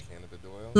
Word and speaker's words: cannabidiol: Cannabidiol.